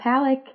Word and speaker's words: Palek: It's Jenna Palek, P-A-L-E-K. A lot of people pronounce it Palek. So whatever you want to go with.